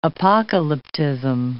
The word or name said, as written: apocalyptism